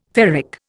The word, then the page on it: ferric: Indian